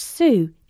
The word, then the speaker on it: Tsu: TSU, pronounced Sue, is a new social network that will pay its users.